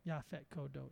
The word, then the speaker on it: Yaphet Kotto: Yaphet Kotto. That's four syllables.